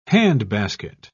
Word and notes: handbasket: British English

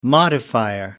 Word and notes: modifier: American English

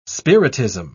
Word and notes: spiritism: British English

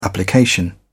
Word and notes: application: British English

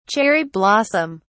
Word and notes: cherry blossom: British English

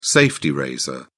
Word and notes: safety razor: British English

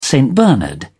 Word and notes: st bernard: British English

